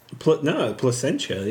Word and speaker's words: Placentia: From Placenta, California. What does it say? – Pasadena? – Placentia, yeah. – Placentia.